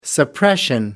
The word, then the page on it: suppression: British English